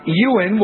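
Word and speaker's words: Euan: British Prime Minister Tony Blair's eldest son, Yuan, is that how you pronounce it? Ewan will work as an intern with Republican staff in the U.S.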